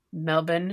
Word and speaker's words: Melbourne: So you live in Melbourne, or I'm probably saying that incorrectly. Melbourne. Is how you would say it. Australia. – Yes, Melbourne. – Yeah. from the United States, it looks like Melbourne, which is completely wrong.